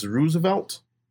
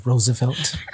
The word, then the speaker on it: Roosevelt: Since Roosevelt... Since he became president and he enacted a lot. What? – Go ahead, sir. – It's pronounced Roosevelt. – I'm saying Roosevelt.